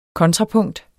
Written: kontrapunkt

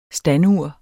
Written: standur

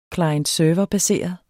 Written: klient

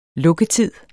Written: lukketid

